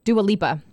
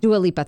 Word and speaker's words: Dua Lipa: Sierra, Camila Cabello, and Dua Lipa? No, am I saying it wrong? – Dua Lipa. – Dua Lipa, thank you.